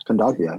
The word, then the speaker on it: Kondogbia: Kondogbia. Yeah. – Kondogbia.